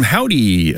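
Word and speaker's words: Hovvdy: New music from Howdy up next, although it's spelled with two V's and no W.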